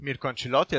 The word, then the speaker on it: Mirko Ancillotti: Yes, I'm Mirko Ancillotti, as you well said, well pronounced.